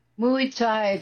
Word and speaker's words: Muay Thai: Muay Thai, that's how you say it.